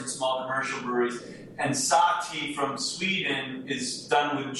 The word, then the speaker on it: sahti: And by the way, our version is spelled S-A-H-T-E-A, so that the word tea is in there. But traditionally, there is a beer called SahTea, S-A-H-T-I.